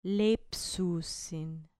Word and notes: leipsusin: grec

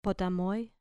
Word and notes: potamoi: grec

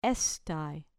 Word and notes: estai: grec

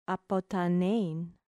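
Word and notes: ἀποθανεῖν: ap ʌ t h an ɛ i ː n; apothanein

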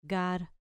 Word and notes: gar: grec

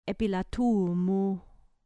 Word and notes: epilatu mu: grec

